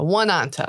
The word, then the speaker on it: Oneonta: Yeah, and it's spelled O-N-E-O-N-T-A. – Yes. – Oneonta, Oneonta. – Oneonta. – Not two, just one.